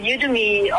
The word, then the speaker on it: Udemy: One of FNB's eBucks partners is Udemy. Is that how it's pronounced? Udemy. – Udemy, that's correct.